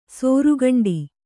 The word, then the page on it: ಸೋರುಗಂಡಿ: sōru gaṇḍi